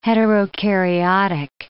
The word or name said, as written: heterokaryotic